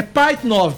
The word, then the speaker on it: Pajitnov: Pajitnov. Alexei Pajitnov. Tá bom, né? – Tá. – Ou Pajitnov. Alexei Pajitnov. É por aí. Ele trabalhava no Centro de Computação da Academia Russa de Ciências. É Pajitnov, porque o J não tem.